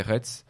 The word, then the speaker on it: Retz: On trouve énormément de choses chez eux, c'est Retz. Ça s'écrit R-E-T-Z.